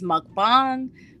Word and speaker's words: mukbang: The original pronunciation, I believe, is mukbang.